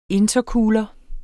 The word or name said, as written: intercooler